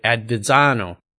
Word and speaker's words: Avezzano: Avezzano. Now I said that right? – That's correct. Yeah, the, the two Z's come off as a D.